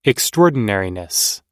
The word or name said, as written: extraordinariness